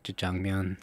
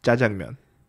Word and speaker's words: jajangmyeon: Why do you say it like that? – Say what? – Jajangmyeon. And that's what I said, jajangmyeon. – No, you did. you put the emphasis on the wrong syllable.